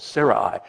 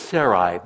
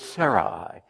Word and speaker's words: Sarai: Now, Abram, and by the way, Karen, I love the way you pronounced Sarai. I thought that was wonderful, Sarai. I've always said Sarai, but you're right, it's Sarai. And I'm going to try to take your example and say it right.